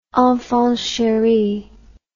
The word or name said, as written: enfant cheri